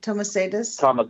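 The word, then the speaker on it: Tamosaitis: Do you think that the whistleblower, how do you say his last name? Thomasaitis? – Thomasaitis.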